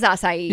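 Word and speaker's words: açaí: Yeah, it's acai, right? – It is acai. – Yeah. – But a lot of people don't say that. They say like acai. – Acai berry.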